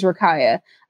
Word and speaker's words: Rakia: I'm pretty sure her name is Rakia. I'm reading the Hangul, and it's pronounced like Rakia.